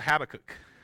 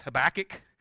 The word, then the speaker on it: Habakkuk: We're going to be in a book called Habakkuk, which for those who grew up in the South, it's Habakkuk, because that's how we pronounced it, and I was corrected by a Jewish person, and I was like, well, I won't pronounce it that way anymore then.